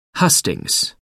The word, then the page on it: hustings: American English